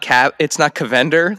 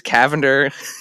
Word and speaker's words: Cavender: You taught me it's not Cavender like Avengers. It's Cavender.